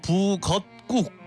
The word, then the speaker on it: 북엇국: 국은 한글이고 또 순우리말이고 또 발음 보면은 북어국, 북엇국 이래가지고 뒤에가 꾹으로 돼요. 꾹.